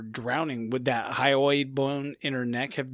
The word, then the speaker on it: hyoid: The hyoid bone? – It's the hyoid bone.